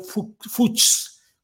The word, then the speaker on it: Fuchs: se escribía Fuchs, como fuchis, ya ven ustedes que luego, bueno, como fuchis, pero sin la I, fuchs, pero la pronuncian algo así como fuchs, que es el fox, que es el zorro en inglés y que es el origen de este apellido.